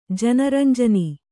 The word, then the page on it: ಜನರಂಜನಿ: jana ranjani